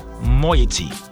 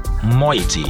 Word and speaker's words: moiety: But the correct answer is moiety. Moiety. Moiety is spelled M-O-I-E-T-Y.